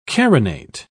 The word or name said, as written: carinate